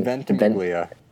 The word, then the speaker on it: Ventimiglia: Ventimiglia.